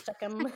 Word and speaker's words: Shechem: Shechem.